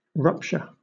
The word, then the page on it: rupture: British English